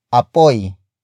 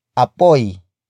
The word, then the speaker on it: apoy: And that would be apoy. Apoy. Apoy. Apoy is spelled as A-P-O-Y.